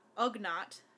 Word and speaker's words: Ugnaught: IG-88 in episode 5 that is killed by Boba Fett on Cloud City and can be seen in the Ugnaught. Did I say it right? – Yep.